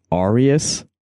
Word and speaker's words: Ariias: And perhaps more importantly, you also play guitar and keyboard and do some vocals in the band Arius.